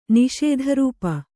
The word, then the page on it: ನಿಸ್ತ್ರಪ: nistrapa